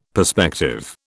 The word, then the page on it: perspective: British English